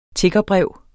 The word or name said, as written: tiggerbrev